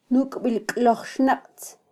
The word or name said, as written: nukbilklojxnaqtz